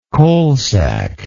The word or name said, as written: coalsack